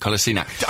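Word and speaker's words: Kolašinac: I think it's a hard C at the end of it. Kolasinac.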